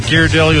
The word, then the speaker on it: Ghirardelli: Ghirardelli.